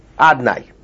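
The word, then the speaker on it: Adonai: In the Beis HaMikdash, outside the Beis HaMikdash, when we read Davin, we say Yud Kei Vav Kei, but we don't pronounce it Yud Kei Vav Kei, we pronounce it Aleph Dalet Nun Yud, Adnai.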